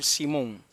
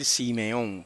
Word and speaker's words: Simón: Y ese es más correcto decir Simón que decir Simeón, porque esa Shewa que tiene ese nombre ahí no se debe pronunciar.